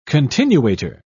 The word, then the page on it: continuator: British English